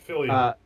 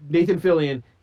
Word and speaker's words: Fillion: Like Nathan Dillon. Can't even say his name now. – Fillion, right? – Nathan Fillion.